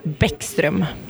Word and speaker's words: Bäckström: You're listening to First Chair, and we have a wonderful guest, Cecelia Bäckström, which I horribly pronounced your last name because there are umlauts over the A and the O, so I would love for you to pronounce that name correctly for us. – Uh, so it's uh, Cecelia Bäckström.